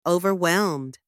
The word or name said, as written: overwhelmed